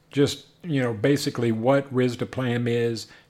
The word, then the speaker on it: risdiplam: Another drug, which is called, I'm probably going to butcher the pronunciation here, but I'm going to say risdiplam. Is that, I don't know. – It's actually pronounced risdiplam.